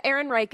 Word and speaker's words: Ehrenreich: Yeah, Ehrenreich is the American pronunciation. I'm not even going to attempt the German pronunciation because I'm very bad at that.